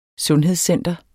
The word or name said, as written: sundhedscenter